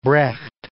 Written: Brecht

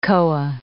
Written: koa

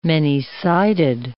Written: many-sided

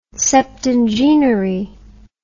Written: septingenary

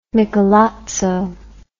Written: Michelozzo

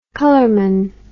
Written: colorman

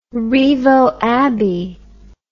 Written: rievaulx abbey

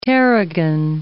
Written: tarragon

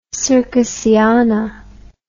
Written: circusiana

